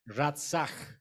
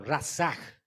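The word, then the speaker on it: ratzach (רצח): Es una palabra hebrea que, pues, la pronunciación es ratzach. Es lo que está diciendo este verbo, ratzach y era conocido por todos los hebreos.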